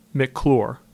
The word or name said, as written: McCloor